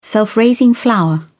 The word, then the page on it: self-raising flour: British English